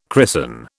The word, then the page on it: christen: British English